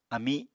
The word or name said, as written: ami